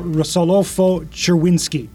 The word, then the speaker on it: Rosolofo-Czerwinski: Joining me today is Rachelle Rosolofo-Czerwinski.